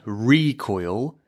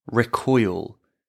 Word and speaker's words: recoil: But this is the noun, recoil. If we use the verb, we put the stress on the second part, which is recoil. Recoil.